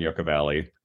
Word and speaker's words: Yucca Valley: It's actually, your other house is in Yucca Valley. Am I saying that correctly? – Yeah, technically both houses are in Yucca Valley, which is the town canceled.